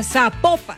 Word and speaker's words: Zapopan: Nos escriben los oyentes y que no se, no se pronuncia Zapopan, sino que se pronuncia Zapopan.